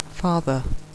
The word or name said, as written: father